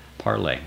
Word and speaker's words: Parler: I know I always say it Parler because people are trying to type it. And most people, I hate to have to keep saying you spell it Parler, you pronounce it Parlay.